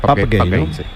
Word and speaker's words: Pape Gueye: Le pregunté y me dijo la pronunciación correcta, que es Pap Gay.